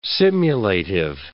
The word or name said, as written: simulative